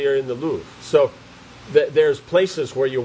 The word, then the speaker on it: Louvre: Louvre.